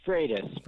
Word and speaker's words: Freitas: Freitas or Freitas? – It's Freitas, but you were close.